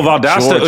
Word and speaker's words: Valdosta: We got Valdez, the state's finest. I gotta correct you. – Did I say it wrong? – It's Virginia State. Valdosta is the one. – No, Valdosta. Excuse me.